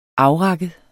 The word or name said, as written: afrakket